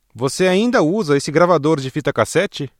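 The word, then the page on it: gravador de fita cassete: Brazilian Portuguese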